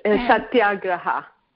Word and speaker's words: Satyagraha: It's Satyagraha. – Okay, Satyagraha. – As far as I can tell, they never let go of a single syllable. So, okay. – Or a single consonant.